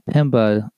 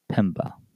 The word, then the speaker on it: Mpemba: And that Pemba does have a silent M. It is M-P-E-M-B-A, Pemba.